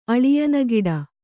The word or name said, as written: ಅಳಿಯನಗಿಡ